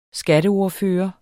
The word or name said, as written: skatteordfører